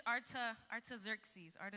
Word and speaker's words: Artaxerxes: Pero Nehemías estaba en otra tierra y él era el copero para el rey, a ver si yo lo digo bien, Artajerjes, right? Artajerjes, that's how you say it, right? In English, Artaxerxes.